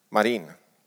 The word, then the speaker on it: Marin: Någon som har koll på hur man uttalar hennes efternamn? Marin. Sanna Marin.